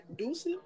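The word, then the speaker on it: Düsseldorf: Dusseldorf. It's the U with the dots.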